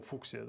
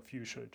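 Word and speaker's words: fuchsia: Вот, ну, есть слово фуксия, да, вот будь добр запомнить, что оно фьючер читается, хотя оно пишется как фуксия.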